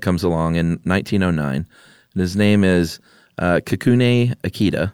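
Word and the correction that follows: Kikunae: There's like an extra little half syllable in there. Kikunaya.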